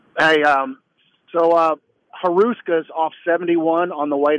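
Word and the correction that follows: Hruska's: Just Ruska's. The H is silent.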